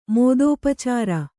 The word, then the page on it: ಮೋದೋಪಚಾರ: mōdōpacāra